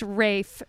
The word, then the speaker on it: Ralph: I love that that's how he pronounces his name. It's not Ralph, folks. It's Rafe for some reason.